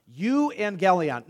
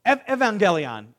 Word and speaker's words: euangelion: Euangelion. Now, I have a friend here who speaks modern Greek. Euangelion in modern Greek is pronounced how? Evangelion. Euangelion became evangelion.